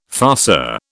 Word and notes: farceur: British English

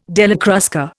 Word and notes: Della Crusca: Indian

